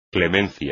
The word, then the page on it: clemencia: espagnol